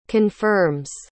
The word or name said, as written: confirms